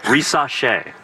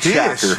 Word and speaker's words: Rissache: Hey, real quick, how do you say the first pick's name again? – The number one pick? – Yeah. – Rissache. Zachary Rissache. – That so it's sounds not like Rishak.